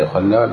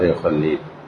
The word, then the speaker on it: يخلى: هكذا يخلى لا يخلي يعني يخلي الله